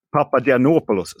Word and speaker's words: Papagiannopoulos: De har ju varit inlånade från Dion och eh, Molde samt en förlängning med Sotirios Papagiannopoulos. Papadianopoulos, så ska, jag, så, så ska jag rätta mitt uttal.